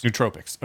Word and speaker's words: nootropics: So going off the topic of recreational drugs and stepping back one to the nootropics. Damn, yeah. How do you pronounce it again? – Nootropics.